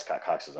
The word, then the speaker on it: Scott Cox: There's a lot of Scott Coxes out there, actually, so I don't know.